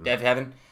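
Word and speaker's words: Deafheaven: The first one is the new record from Deafhaven? I always pronounce it. – I believe it's Heaven. – Deafhaven.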